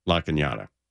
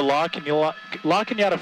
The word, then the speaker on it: La Cañada: And La Cañada Flint Ridge, you've got to really, you know, it's spelled La Canada, C-A-N-A-A-D-A. And unless you're born and raised here, you don't know how they pronounce La Cañada.